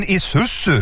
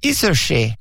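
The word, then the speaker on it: Isuzu: Alltså, du menar din isusu? – Det var ju det jag sa, isushi.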